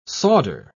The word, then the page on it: sawder: British English